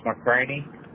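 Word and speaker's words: McCranie: It's McCraney. – Could you spell that? – It's M-C-C-R-A-N-I-E. – R-A-N-I-E? – Yep.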